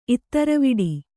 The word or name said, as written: ಇತ್ತರವಿಡಿ